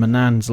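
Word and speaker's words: Manaan: And then like you head over to Manan, and Manan's like this, Manan. That's how to say it.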